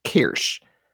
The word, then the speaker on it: Quraysh: I believe it is pronounced Qureysh, is a shark, a giant animal that travels all over the place, and in fact dies if it stops moving.